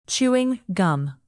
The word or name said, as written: chewing gum